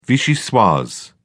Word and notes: vichyssoise: British English

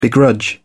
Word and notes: begrudge: British English

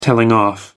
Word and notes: telling off: American English

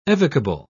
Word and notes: evocable: British English

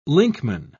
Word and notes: linkman: British English

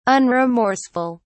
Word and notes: unremorseful: British English

